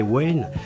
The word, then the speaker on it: Wynne: Et puis euh, l'autre euh, extrait qu'on va écouter, c'est Flippé Wayne. flipper Flippé Wayne, Wayne, Wayne, ça s'écrit W-Y-N-N-E.